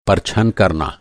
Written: परछन

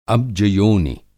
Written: अब्जयोनि

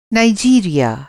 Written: नाईजीरिया

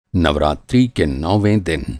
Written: नवरात्री

